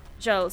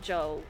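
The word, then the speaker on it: Jal: Jel. So it's J-A-L, but it's like Jel.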